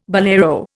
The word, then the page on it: bolero: Indian